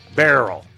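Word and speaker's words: Beryl: We're going to continue this conversation, and we got Burl to talk about. Is that, am I saying it right? Hurricane Burl? – Barrel. – Okay. I'll go with Barrel.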